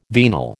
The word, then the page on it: venal: American English